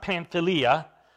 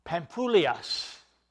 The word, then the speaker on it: Pamphylia: Now, it's not Pamphylia. I know that we like to pronounce it that way, but there's an upsilon there in the Greek. And the way it's actually pronounced in the Greek is Pamphylias.